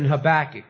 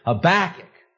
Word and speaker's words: Habakkuk: Habakkuk, Habakkuk. Well, you know, that's not so funny, because in the Hebrew, that's the way it'd be pronounced. The accent being the last syllable.